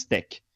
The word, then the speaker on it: TeX: E esse TEC vem, esse X do final do, do TEC, né, T-E-X, na verdade é a letra Q do, do, do grego, então é por isso que a pronúncia não é TEX, mas, mas TEC.